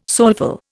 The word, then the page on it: soulful: Indian